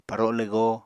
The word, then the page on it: paro lwgo: Apatani